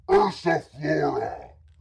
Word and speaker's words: Ursa Flora: Say it like this. Ursa Flora.